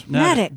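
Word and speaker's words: Neddick: It rhymes with medic, according to this. – Needic.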